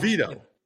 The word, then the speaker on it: DeVito: I feel like you're putting the wrong emphasis on the wrong syllable. It's DeVito. – Didn't I say that? – You're saying DeVito. You're putting the emphasis on his third syllable. The emphasis should be on the second syllable.